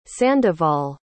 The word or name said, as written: sandoval